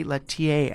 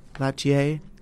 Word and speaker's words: Lietaer: Lettier. I'm not really saying it right. L-I-E-T-A-E-R. Do you know how to pronounce French? Can you say that? – Latier.